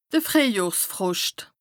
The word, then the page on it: de Frìehjohrfroscht: Bas-Rhin; Strasbourg